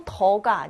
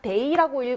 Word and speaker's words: 得: 자, 여기서 읽을 때 얘도 더가 아니라 데이라고 읽어주셔야 되는 거 중요하죠.